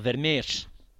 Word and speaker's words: Vermeersch: ¿Cómo lo pronuncias tú? – Florian Vermeersch. – Muy bien.